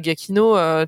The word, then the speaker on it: Giacchino: Je sais jamais si je le prononce bien ou pas. Giacchino. – Bon, je valide.